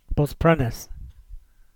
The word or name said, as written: Bosprennis